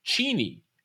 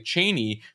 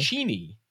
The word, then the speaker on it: Cheney: Cheney was the pronunciation of their name. But when he got to Washington, everyone kept mispronouncing it Cheney. So he just accepted Cheney at some point. But officially, it's Cheney.